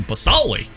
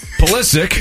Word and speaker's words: Pulisic: Christian Pasoli. – Pulisic.